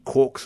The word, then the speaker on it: quark: And he found a reference in James Joyce's Finnegan's Wake, which is the line, three quarks for Muster Mark, which makes you think it should be pronounced quark, and most people do think it should be pronounced quark, but he had this weird, complicated justification why it could still be pronounced quark, even though it looks like it's meant to rhyme with the word mark.